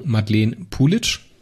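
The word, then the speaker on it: Madeleine Puljic: Das wird ausgesprochen Madeleine Pulic.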